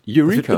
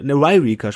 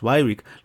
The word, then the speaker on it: Yreka: Eureka. – Das wird YR. Eureka. Ne, Yreka sprechen die das. Yreka. YRKA. – Ah. – Ein Y, dann direkt ein R dahinter. Das ist Yreka. – Yreka.